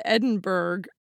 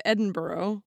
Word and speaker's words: Edinburgh: So Eric pointed out to me that you said Edinburgh instead of Edinburgh.